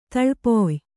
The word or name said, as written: ತಳ್ಪೊಯ್